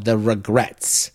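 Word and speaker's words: The Regrettes: And then the next act we're going to play is called The Regrets. And that's spelled R-E-G-R-E-T-T-E-S, like regrets.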